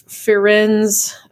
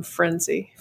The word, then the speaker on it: Firenze: and it's Firenze. It's the way that you say Florence. – Firenze. – I would not call him Frenzy. Firenze. – Firenze. Sorry. – That's how you pronounce it. That's what Florence is in Italy. That's how you say it in Italian.